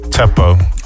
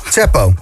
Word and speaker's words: Tsepo: Maar hoe mag ik het eh, uitspreken? – Tsepo. – Tsepo.